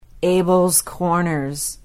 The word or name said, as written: abells corners